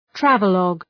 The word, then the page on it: travelogue: ‘trævə,lɒg